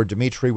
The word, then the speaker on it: Dimitri: It's easy to use, And look for Dimitri with three I's, D-I-M-I-T-R-I.